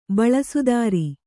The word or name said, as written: ಬಳಸುದಾರಿ